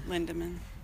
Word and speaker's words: Lindeman: You know, you say it wrong every time. – Lindeman. – Yeah. – And I said, Lindman. You forget the D.